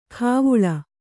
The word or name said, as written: ಖಾವುಳ